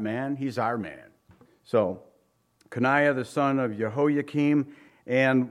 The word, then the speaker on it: Coniah: Here, if you look at it, it says Zedekiah, the son of Josiah, reigned instead of Coniah, or Coniah, many say, but it's pronounced Coniah, the son of Jehoiakim.